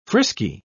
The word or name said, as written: frisky